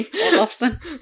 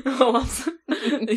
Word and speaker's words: Olofsson: I was right. I felt right. – What? – The pronunciation. – Okay. – Olofsson. – Olofsson. You both were like, I'm like, okay. – Sounds about right.